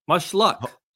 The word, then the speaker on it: Mushaluk: She's 34 years old, and it's pronounced mushaluk. Mushluck. I wish her much luck.